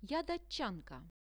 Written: ja datchanka

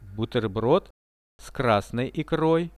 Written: buterbrod s krasnoi ikroi

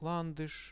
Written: landush

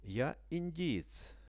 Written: ja indiec

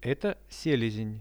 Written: eto selezen

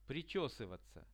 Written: prichesyvatsia

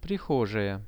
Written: prihojaia

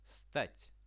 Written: stat

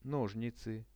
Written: nozhnicy

